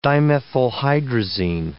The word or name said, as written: dimethylhydrazine